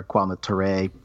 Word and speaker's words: Kwame Ture: during the scene where Kwame Ture, uh, I'm sorry, I might be mispronouncing his name. – Kwame Ture, um, you got uh, it. You got it great.